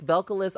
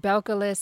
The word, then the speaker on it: Belcalis: Well, that is, it's Belcalis Almanzar. That's her name. – Belcalis.